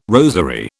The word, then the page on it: rosary: British English